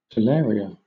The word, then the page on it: talaria: British English